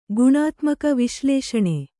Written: ಗುಣಾತ್ಮಕ ವಿಶ್ಲೇಷಣೆ

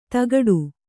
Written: ಧಕ್ಕಡಿ

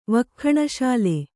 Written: ವಕ್ಖಣಶಾಲೆ

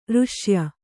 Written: ಋಶ್ಯ

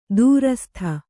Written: ದುರಾಸದ

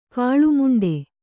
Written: ಕಾಳುಮುಂಡೆ

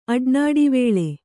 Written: ಅಡ್ನಾಡಿವೇಳೆ

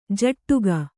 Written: ಝಾಡಿಕೆ

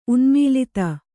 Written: ಉನ್ಮೂಲಿತ

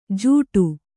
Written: ಜೂಟು

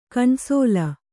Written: ಕಣ್ಸೋಲ್

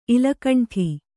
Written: ಇಲಕಂಠಿ

